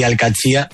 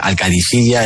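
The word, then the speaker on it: Al-Qadsiah: Bueno, se suele decir Al-Katsi, pero también hay gente que dice Al-Kadisiyya. Es que es difícil, ¿eh? No es fácil, pero Al-Katsi es lo más fácil.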